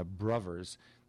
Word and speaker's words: Bruvvers: And there was a guy called Joe Brown, and with his band Joe Brown and the Bruvers, that South London pronunciation of brothers with B-R-U-V-V-E-R-S,